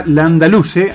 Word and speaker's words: Landaluce: Bueno, decíamos que venía a ganarle justamente a Djokovic, ahora le ganó a Landaluce, así se pronuncia, ajá, Martín Landaluce, por doble 7-6 y se metió en la siguiente ronda del Master 1000 de Cincinnati.